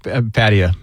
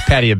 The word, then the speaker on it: Pattaya: Yes, it's pronounced Pattaya. It's Pattaya Beach.